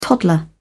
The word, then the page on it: toddler: British English